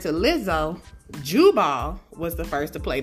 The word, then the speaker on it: Jubal: Jabal, okay, and Jubal. J-A-B-A-L and J-U-B-A-L.